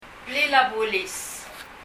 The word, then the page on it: blil a bulis: blil ə bulis